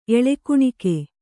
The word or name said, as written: ಎಳೆಕುಣಿಕೆ